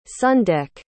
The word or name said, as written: sundeck